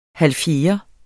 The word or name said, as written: halvfjerde